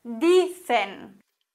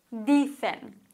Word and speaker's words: dicen: Dicen. Hier haben wir jetzt ein Wort, das auf N endet. Und auch hier wird die vorletzte Silbe betont und wir haben keinen Akzent. Dicen.